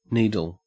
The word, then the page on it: needle: Australian English